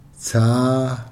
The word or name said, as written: tsaa